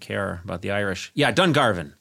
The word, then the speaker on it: Dungarvan: Conan, how do you say this? Dungarvan? – Dungarvan. You barbarian.